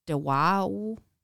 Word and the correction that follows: DePauw: What are you pronouncing? – It's DePAUW.